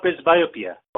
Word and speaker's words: presbyopia: How do you say the word again? – It's called presbyopia. – Oh, pres pres Presbyopia, presbyopia.